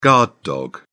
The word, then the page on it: guard dog: British English